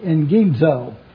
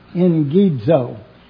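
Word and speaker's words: engizo (ἐγγίζω): engizo, E-G-G-I-Z-O. In the Greek, when you have two G's, they're pronounced N-G. And that's what I've just done for you, engizo. E-N-G-I-Z-O is the way you would pronounce it.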